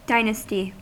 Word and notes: Geschlecht: /ɡəˈʃlɛçt/

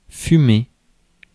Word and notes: fumer: France; [fy.me]

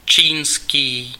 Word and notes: Chinese: [tʃaɪ.ˈniz]; /ˌtʃaɪˈniːz/; /tʃaɪ.ˈniːz/

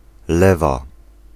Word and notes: lewa: [ˈlɛva]

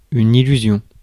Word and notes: figment: /ˈfɪɡ.mənt/

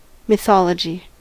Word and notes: mythology: American English; /mɪˈθɑːlədʒi/

